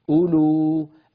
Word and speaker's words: أولو: أولو الواو الأولى فيها تكتب ولا تلفظ. انظروا إليها في المصحف عندكم تجدون عليها سكونا مدورا. السكون المدور معناها هذا الحرف يكتب ولا يلفظ لا في الوصل ولا في الوقف. أولو الذي يلفظ هو الواو الثانية الأخيرة التي في آخر الكلمة.